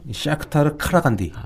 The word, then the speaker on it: 샤크타르 카라간디: 이 기성류 선수가 뛰었던 셀틱, 이 카자흐스탄의 이 뭐라고 읽어야 되죠? 샤크타르 카라간디. 아, 카라간디. 네.